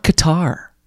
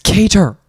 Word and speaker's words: Kattar: And it's not Qatar. Why are people saying that? It's not Qatar. – That hard. – Qatar? – Random people just pronounce people's names like Qatar, Kattar. It's Kattar. – So it's with the T, E-R, Kattar, not Kattar. – K-A-T-T-A-R.